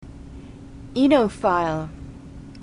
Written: enophile

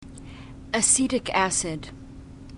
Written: acetic acid

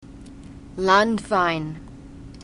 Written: landwein

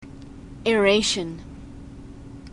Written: aeration

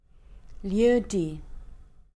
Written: lieu-dit

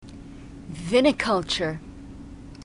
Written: viniculture

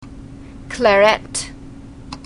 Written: claret

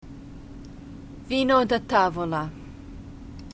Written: vino da tavola

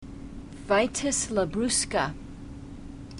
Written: vitis labrusca

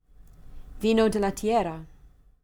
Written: vino de la tierra

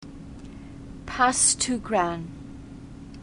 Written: passe tout grains

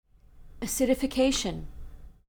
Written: acidification

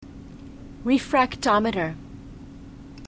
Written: refractometer

